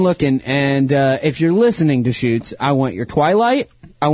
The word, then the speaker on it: Deschutes: I call it Deschutes. D-E-S-C-H-U-T-E-S. – Is that right, Jamie? – Yeah, that's right.